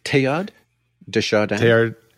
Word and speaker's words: Teilhard de Chardin: Teilhard de Chardin.